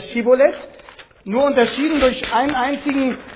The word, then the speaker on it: Schibboleth: Schibboleth bedeutet übersetzt Strom oder Fluss, also dort an den Furten des Jordan, lag ja nahe, Schibboleth auszusprechen. So, sagte er dann Sibboleth, weil ihm die richtige Aussprache unmöglich war, so ergriffen sie ihn und machten ihn an den Jordanfurten nieder.